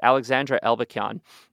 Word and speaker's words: Alexandra Elbakyan: I want to make sure I pronounce her name right, Alexandra Elbakyan.